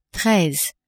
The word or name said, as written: treize